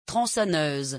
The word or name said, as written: tronconneuse